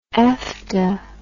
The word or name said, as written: efta